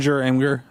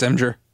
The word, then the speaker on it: Imgur: She took a picture of the garbage can and posted it on is it Imgur? Imgur? How do you say it? – Imgur. I'm pretty sure it's Imgur.